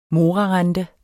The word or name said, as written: morarente